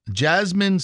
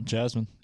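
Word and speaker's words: Jazmine: Jasmine Sullivan, I may be saying that wrong. Is it Jasmine? – Nope, Jasmine. – Jasmine Sullivan. – Yep.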